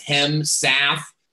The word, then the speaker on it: Hemsouth: My birth last name is Owen Hemsouth, and I'm extremely proud of that name. So it's Hemsouth. And it's pronounced exactly the way that it's spelled.